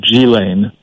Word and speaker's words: Ghislaine: Robert Maxwell, the famous based in the UK, press magnate who died under mysterious circumstances, has a daughter whose name is pronounced, I believe, Ghislaine, but it's spelled Ghislaene.